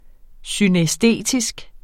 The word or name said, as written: synæstetisk